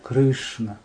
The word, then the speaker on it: Кришна: В слове Харе это на втором слоге Харе, а в слове Кришна и Рама на первом. Кришна, Рама. Не Кришна, Кришна. Кришна, Кришна.